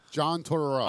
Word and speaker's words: Tortorella: John Tortorella.